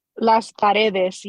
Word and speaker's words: Las Paredes: lost, Paredes, did I say it right? – Las Paredes, yes.